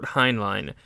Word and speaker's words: Heinlein: So this one, I guess we could always just say, it is Revolt in 2100 by Robert Heinlein.